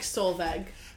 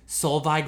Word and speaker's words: Solveig: But I was saying like Solveig. – But underneath she says, Solveig rhymes with Solhi, silent G. Solveig.